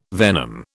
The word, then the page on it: venom: British English